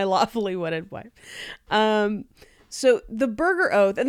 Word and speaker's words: Burgher: And it was actually in response to a law called the Burger Oath, which, and again, it's not spelled like burger. It's B-U-R-G-H-E-R, burger. – Wait, spell it again. – B-U-R-G-H-E-R, it's, burger.